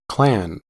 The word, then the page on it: clan: American English